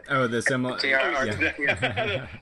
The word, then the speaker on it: Silmarillion: The Silmarillion. – The Silmarillion.